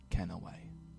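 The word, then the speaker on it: kenaway: pronounced Ken away.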